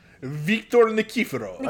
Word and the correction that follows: Nikiforov: I forgot an entire syllable. – Yeah, I'm reading the name and you didn't say it. – Nikiforov.